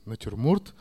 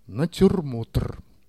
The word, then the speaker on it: натюрморт: Мы до хрипоты спорили, как произносится слово «натюрморт». Я говорил, разумеется, «натюрморт», а Леша уперся. «Натюрмотр». Видимо, по аналогии с Монмартром, Сартром, театром.